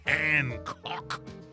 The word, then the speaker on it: Hancock: So Matt Hancock, we've covered Matt Hancock before, and you have to pronounce his name like that. Hancock. It's a strong name, rigid.